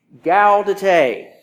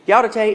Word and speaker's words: Gaudete: Today is Gaudete Sunday, and I had to check with the Latin scholar here on the correct pronunciation of that word. Gaudete. Gaudete is Latin for rejoice.